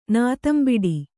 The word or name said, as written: ನಾತಂಬಿಡಿ